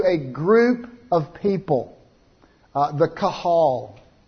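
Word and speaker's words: qahal: It's the first word I have up here on the board, and it's pronounced kahal. It's kahal, and I'm not exactly sure how it comes over. I think it's Q-A-H-A-L. Kahal.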